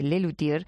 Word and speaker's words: Luthiers: que valiéndose de la S final del plural de Les Luthiers, no se pronuncia,